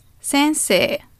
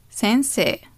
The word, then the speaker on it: 先生: Maybe you have heard 先生 before.Basically, sensei is a word which lots of people pronounce in the wrong way. I hear like sensei or sensei or whatever, but it's sensei, sensei. So it's long e at the end, sensei.